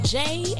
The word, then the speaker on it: Jaie: This is your girl, Jay, and welcome to the J Spot, and that J is spelled J-A-I-E.